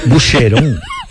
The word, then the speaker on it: Boucheron: ¿Se escribe Boucheron? ¿Boucheron? – Sí, Boucheron, sí. Pues, como el Boquerón, acá. – No así, hombre, eso, Boucheron. – Boucheron. – Suena en francés, Boucheron.